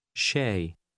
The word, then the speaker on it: شاي: How do you say just tea in Arabic? – Shay. Shay.